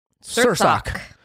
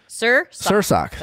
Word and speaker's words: Sursok: Sursok. – Sursok. That's how she instructed us how to say her name.